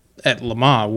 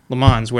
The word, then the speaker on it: Le Mans: Correct, but it's not like at Le Mans where they... Le Mans. I don't know why I pronounce it like that. Le Mans, where they do the overall podium.